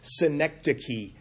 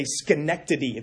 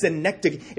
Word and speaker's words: synecdoche: I just this past week learned how to pronounce that word and worked to make sure I didn't say Schenectady. It's not the city in New York. It's synecdoche.